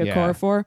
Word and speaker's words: Okorafor: Okorafor.